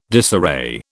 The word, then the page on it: disarray: British English